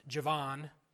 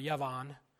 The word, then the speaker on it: Javan: So if you pronounce that Hebrew word, it's not Greece. It's actually pronounced Javan or Yavan.